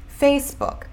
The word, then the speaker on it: Facebook: Então, a pronúncia seria Facebook. Facebook, com duas sílabas, não é Facebook.